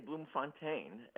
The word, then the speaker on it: Bloemfontein: Well, I usually say Bloemfontein, and, his dad was one of these Brits who went in search of a better living.